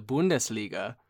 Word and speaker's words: Bundesliga: Bungula? – I'm just gonna bunga. Let you keep trying. – This is Connor trying to pronounce the German soccer league, the Bundesliga.